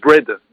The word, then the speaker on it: Braid: En 1841, il publie, euh, il s'appelle James Bread, que ça s'entend comme le pain, mais ça s'écrit B-R-A-I-D, donc à vos Google, chers tous.